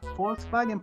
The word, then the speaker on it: Volkswagen: Volkswagen se pronuncia Volkswagen porque la B corta que nosotros conocemos se pronuncia como V acá.